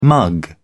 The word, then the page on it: mug: British English